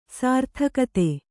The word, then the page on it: ಸಾರ್ಥಕತೆ: sārthakate